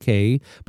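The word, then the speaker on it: Cay: We pronounce it K, but it is Key, but we say K, C-A-Y.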